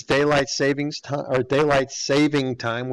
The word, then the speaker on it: daylight saving time: Remind everybody that it's daylight saving time weekend. I was listening to the news earlier, and I guess I've been saying it wrong for a long time. Daylight saving time.